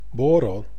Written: bårå

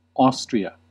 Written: Austria

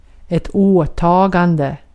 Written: åtagande

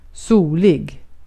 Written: solig

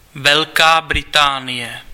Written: United Kingdom

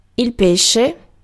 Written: poisson

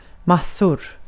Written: massor

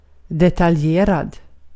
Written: detaljerad

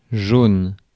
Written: jaune

